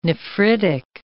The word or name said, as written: nephritic